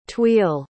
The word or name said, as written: tuille